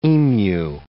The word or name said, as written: emu